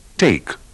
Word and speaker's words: take: Badate bene ai tranelli della pronuncia come la E muta alla fine di change, arrive, take.